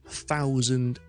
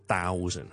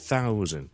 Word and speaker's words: thousand: 一千 thousand thousand thousand。第一件事要留意就是，一开头那个不是 D 音来，我们不要读 thousand，是 thousand th 音，跟住出气的脷尖放在上面那块门牙的下面，是好细声的。